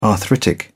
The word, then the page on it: arthritic: British English